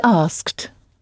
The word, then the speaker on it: asked: He asked. – He asked, oui, qui s'écrit donc « a-s-k-e-d », mais qui se prononce comme une seule syllabe, avec le « ed » final prononcé « t ».